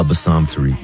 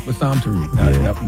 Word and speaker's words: Bassam Tariq: Bassam Tariq. – Bassam Tariq.